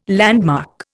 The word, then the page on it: landmark: Indian